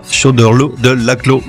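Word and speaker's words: Choderlos de Laclos: Oui, alors tu peux y aller, ça se prononce comme ça, Chauderlot de Laclos, oublie les S finaux.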